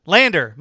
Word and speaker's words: Lander: Did I say, what did I say? Lighten? – Landon. – Landon. Lander. My apologies, Lander.